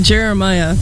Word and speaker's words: Jeremih: How do you pronounce his name? Because I honestly don't know. J-E-R-E-M-I-H. Jeremiah? Jera something. I don't know. Here's birthday sex. – I'd pronounce it Jeremiah, but... – There you go.